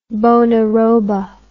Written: bonaroba

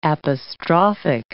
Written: apostrophic

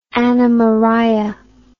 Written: anna maria

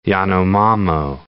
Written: Yanomamo

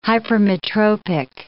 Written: hypermetropic

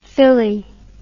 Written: fili

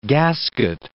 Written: gasket

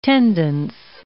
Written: tendance

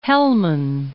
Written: Hellman

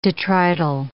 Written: detrital

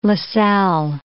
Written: Lassalle

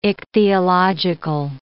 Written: ichthyological

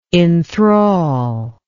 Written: inthral

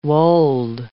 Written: wold

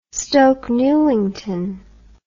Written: stoke newington

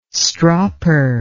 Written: stropper

